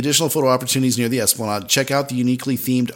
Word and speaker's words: Esplanade: Starting on October 1st, there will be additional photo opportunities near the Esplanade, or as Karin says, Esplande. Which, this is a funny story. I've known multiple people to think that the Esplanade is pronounced Esplande. So if you're one of those people, you're wrong.